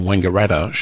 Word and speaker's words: Wangaratta: Historically, there's been disagreement about whether the first syllable in Wangaratta should be pronounced Wong or Wang.